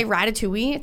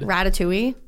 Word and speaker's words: Ratatouille: I can't say Ratatouille. It sounds very weird when I say it. – That's how it's said. – Ratatouille? – Yeah.